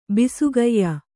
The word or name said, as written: ಬಿಸುಗಯ್ಯ